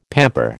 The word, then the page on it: pamper: American English